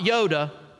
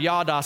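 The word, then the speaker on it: yada (יָדָה): It's the Hebrew word yada. We would spell it Y-A-D-A. Not Yoda, yada.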